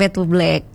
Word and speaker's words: Fade to Black: Terus juga ada Mas Amin di Sumber Pucung yang minta lagunya Bondan featuring Too Black ya, featuring apa sih bacanya? Featuring Too Black atau Fade to Black kali ya? Iya, featuring Fade to Black, Bumi ke Langit.